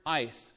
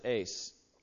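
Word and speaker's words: ice (εἰς): Now, that little Greek word looks like a E-I-S kind of looking thing. That's the Greek word ice. Some people pronounce it ace.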